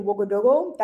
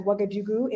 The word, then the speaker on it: Ouagadougou: I grew up in Northern California, and I currently live in Ouagadougou. That is the correct Moray pronunciation of the city that's better known as Ouagadougou in French and English.